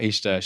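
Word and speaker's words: Ixta: I'm going to let you tell me how to pronounce her first name. – This is, I think I might be saying it wrong, but it's Ishta Belfrage. – Ishta.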